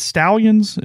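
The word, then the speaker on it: Stalions: I mean, reading from ESPN, Connor, is it Stallions? Is that how we're pronouncing his last name? – I think so. – There's not two L's, but I'm going to assume phonetically that is correct.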